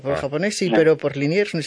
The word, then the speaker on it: Liniers: Liniers. ¿Se dice así? – Sí, Liniers, un Santiago argentino.